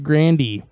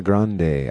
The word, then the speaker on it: Grande: Is it really Grande? – Yeah. – If I would have known that, I would have pronounced it Grandy. – It's Ariana Grande.